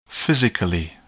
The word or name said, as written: physically